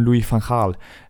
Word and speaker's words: Louis van Gaal: Louis van Gaal ähm, ersetzt. Warum ich da jetzt so, so einen Akzent gemacht habe, weiß ich auch nicht. Aber immerhin richtig ausgesprochen.